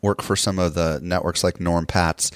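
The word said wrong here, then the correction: Pattiz: I think I'm saying his name right, the guy that started Westwood One and is with Podcast One. – Pattis.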